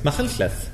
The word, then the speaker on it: Machynlleth: Machynlleth.